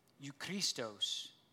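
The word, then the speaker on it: euchrestos: And useful is a word closely connected with the other one that's pronounced eukristos.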